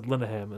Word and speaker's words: Linehan: I've always called him Linehan. And then it wasn't until that Harmontown when they said Linehan. And that's apparently how his name is pronounced.